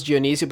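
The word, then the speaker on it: Dyonisio: My name, Carlos Dionisio.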